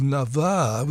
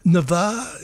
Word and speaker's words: Navarre: And Polly Baguez, from a duo now called Navarre, with the accent on the second A. Navarre, is that how you'd pronounce it?